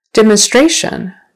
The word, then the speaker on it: demonstration: Demonstration. Now it's the third syllable.